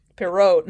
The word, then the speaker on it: Pierrot: Pierrot. No, you do not pronounce the T at the end.